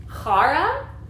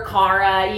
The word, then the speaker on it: chara: It's chara. Or Kara, you know, if you want to say it in simple terms, it's C-H-A-R-A.